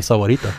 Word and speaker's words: Sahuarita: I salute you on your pronunciation of Sahuarita.